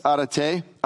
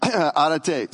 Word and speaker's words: arete: So this word uh, virtue in the, in the Greek, it's arete, arete, and I'm, i actually had that on good authority. I actually called my son yesterday and my daughter. They were together, and both of them confirmed I'm saying it right now.